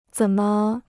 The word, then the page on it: 怎么: zěn me